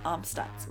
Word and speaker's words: Amstutz: I am on all social media platforms at Sandra Amstutz. My last name is spelled AMSTUTZ.